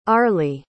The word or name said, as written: arlie